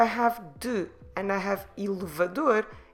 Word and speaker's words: elevador: Elevador. Elevador. Elevador. Como se escreve elevador? E-L-E-V-A-D-O-R.